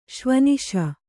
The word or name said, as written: ಶ್ವನಿಶ